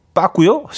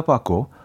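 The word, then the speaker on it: Paco: 빠, 빠꼬입니다. 빠꼬. 어, PACO인데, 어, P를 이제 빠 발음하기 때문에, 어, 스페인에서. 빠꼬 델 루치아. 예, 연주로 들려드렸고요. 어, 빠꾸씨 아니고요.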